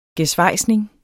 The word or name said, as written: gesvejsning